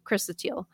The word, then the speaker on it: chrysotile: Now, granted, this was lizardite, so it wasn't chrysotile. Did I pronounce that right? Is it chrysotile or chrysotile? – Chrysotile. – Chrysotile, yeah.